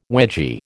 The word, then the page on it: wedgie: American English